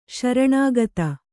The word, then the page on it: ಶರಣಾಗತಿ: śaraṇāgati